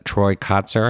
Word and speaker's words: Troy Kotsur: Ben Affleck in the Tender Bar, Bradley Cooper in Licorice Pizza, Troy Kotsur, I think his name is pronounced, in Coda, Jared Leto, House of Gucci, and Cody Smith McPhee, The Power of the Dog.